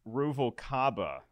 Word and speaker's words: Ruvalcaba: On behalf of Producey Lucy, Christian Rubikawa. Christian, I said your name wrong. I'm sorry. Rubikawa. – You got to hit the B where you hit the W.